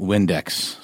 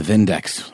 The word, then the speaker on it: Vindex: And that is not Windex, it's pronounced with a German accent. It's the Vindex with a V.